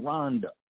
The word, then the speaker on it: Rwanda: We say Rwanda because the W comes right after, but he says in Rwanda, the Hutus and the Tutsi conflict, and how I believe it was the Hutus who had the fleet.